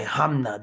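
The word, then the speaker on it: Hanma: Yujiro, Yujiro Hamna. – Yeah, Hamna. There you go, Hamna. I'm I'm over here breaking all the syllables there down.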